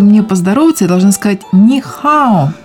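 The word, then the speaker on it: «нихао» (你好): поэтому первый тон для первого слога, он переходит во второй тон, и получается первый слог мы произносим вторым тоном, как с удивлением «ни», а второй слог произносим третьим тоном, уже нисходящий и восходящий «хао». В итоге мы получаем «нихао».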